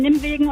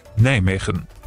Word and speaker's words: Nijmegen: Richtig ausgesprochen, die Niederländer sagen so. – Nijmegen. – So, jetzt wissen wir es, für alle mal geklärt.